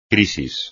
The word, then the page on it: crisis: espagnol